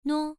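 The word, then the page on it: nuo: tone 1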